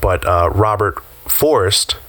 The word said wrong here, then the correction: Forster: Is it Forrest? – I mean, Forster. – No, it's Forster. It is Forster.